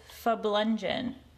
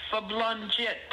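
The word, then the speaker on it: farblunget: This week, the word is fablungen. – Oh, it's fablungit. – Farblungit. – Yeah, with a... – And is the R silent in the beginning, or is that pronounced, is it farblungen or fablungen? – F-A, fablungit, with not a D, but a T. – With a T, okay. – A T, fablungit.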